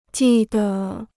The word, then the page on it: 记得: jì de